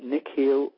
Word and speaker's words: Nikhil: Mr. X, his name is Nikhil Parekh, and he might correct me on the pronunciation, but Nikhil is spelled N-I-K-H-I-L, Nikhil Parekh, and he's been a full-time affiliate marketer since June 2005.